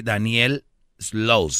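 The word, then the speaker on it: Daniel Sloss: El brother se llama Daniel, así, Daniel Slows, se escribe igual, Daniel, y luego S-L-O-S-S.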